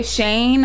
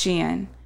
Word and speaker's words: Shein: I heard some people say Shane, and it me is not too. Shane. – It's Shein.